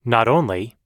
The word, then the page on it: not only: American English